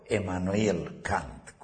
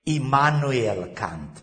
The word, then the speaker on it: Immanuel Kant: Filozoful Immanuel Kant, sau Emmanuel Kant, cum vreți să pronunțați, eu l-am spus englezește, Immanuel Kant, pardon, nemțește,